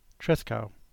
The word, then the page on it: Trescowe: English